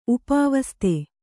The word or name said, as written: ಉಪಾವಸ್ತೆ